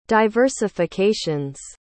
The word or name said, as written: diversifications